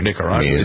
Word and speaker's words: Nicaragua: Nicaragua.